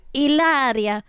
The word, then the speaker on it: Hilaria: And if you don't know about the Hilaria Baldwin story, and when I say Hilaria, you know, I'm talking about Hilaria, but I pronounce it how she pronounces it, which is Hilaria. That's kind of Italian sounding.